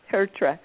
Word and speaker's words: Tertre: Oh, everybody pronounces it differently, so it doesn't really matter. And I tell them if they get really confused, just think of two turtles. Two turtles. Tertre.